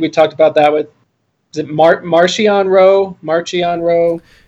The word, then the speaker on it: Marchion: I was totally off with my pronunciation, but it's Marchion.